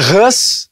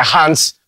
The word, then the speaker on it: Hans: as pessoas vão falar meu nome, tem gente que chama de Hans. – Hans. Né? mas Hans a, em cima. É, mas a minha mãe diz, não, é Hans.